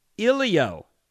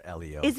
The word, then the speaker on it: Elio: Ilio. E-L-I-O.